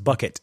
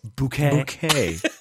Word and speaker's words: Bucket: Mrs. Bucket. – Bouquet.